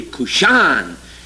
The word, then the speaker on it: Cushan: All this English anglicization. It should be Cushan. The accent's always on the last syllable.